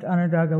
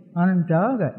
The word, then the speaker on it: Onondaga: Onondaga, it was kind of anglicized by people that came round. The actual pronunciation is Onondaga. That's the way that we pronounce what we call ourselves.